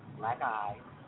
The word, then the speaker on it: BLAQUEAHYS: We're on Facebook, and it's BLAQUEAHYS. It's pronounced Black Eyes. You can friend us on Facebook. We're on Twitter, same way, Black Eyes.